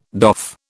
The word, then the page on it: doff: British English